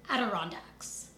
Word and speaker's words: Adirondacks: So 2 years after moving back east, the entire Brown family is going to move to upstate New York by the Adirondacks. Did I say that right? Okay, good. I'm really bad at pronouncing, like, East Coast place names because California.